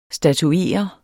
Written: statuere

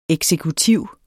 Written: eksekutiv

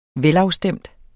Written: velafstemt

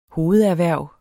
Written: hovederhverv

